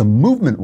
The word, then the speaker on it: MVMT: It's spelled MVMT, so you don't have to pay for all those expensive vowels.